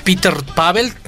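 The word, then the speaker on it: Petr Pavel: Porque justo el presidente de la República Checa, Peter Pavel, espero lo haya pronunciado bien.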